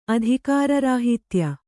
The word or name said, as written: ಅಧಿಕಾರರಾಹಿತ್ಯ